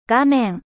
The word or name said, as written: ecran